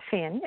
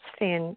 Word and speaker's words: Finn: So the only difference in my name is that my name is spelled with an F instead of a P-H, and it's Finn, F-I-N-N.